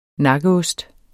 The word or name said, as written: nakkeost